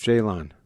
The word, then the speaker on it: Jalon: Jalon, is it, how do I pronounce this? – I think it's Jalon.